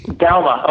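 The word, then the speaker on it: Douma: Yeah, I think one of the ones, and I'm not sure if I'm pronouncing the name right, is Joachim Duma, The Ten Commandments, published by PNR. – Oh, Joachim Dauma. – Dauma, okay, I figured I was going to butcher it.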